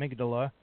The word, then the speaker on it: amygdala: Amygdala.